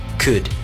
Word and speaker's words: could: Actually, there's no L cut. When you pronounce it. – Right. So the correct pronunciation would be could.